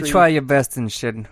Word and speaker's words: Enschede: It's a city called Enschede. – Mm-hmm. That was it? – Yeah. – Enschede? – E-N-S-C-H-E-D-E. – Enschede. Oh, Enschede.